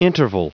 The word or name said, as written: interval